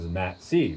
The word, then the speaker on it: Matt C.: That I said it. I was like, Matt-C.